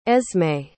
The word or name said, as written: esme